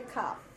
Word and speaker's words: kaf: This red kaf is pronounced as like the chet, another guttural, another coughing lady. You know? Chet, kaf. The other one is hard kaf, okay? Like in, as in kai. Ha, as in Bach, and ka as in kite.